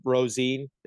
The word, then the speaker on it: Rosen: So there's I guess you say his name, Rosin. It's spelled Rosen.